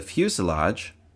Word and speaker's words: fuselage: The fuselage, rhymes with blue garage, is the largest part of the plane.